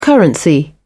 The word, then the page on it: currency: British English